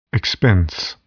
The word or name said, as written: expense